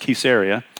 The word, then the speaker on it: Caesarea: Jesus, he comes to the region of, I know we all want to say Caesarea, or I don't know, however everybody says it. It's pronounced Caesarea. It's Caesarea Philippi.